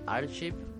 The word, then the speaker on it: Archive: del grupo Archive, ¿cómo se dice? – Sepala. Archivo, como archivo en inglés.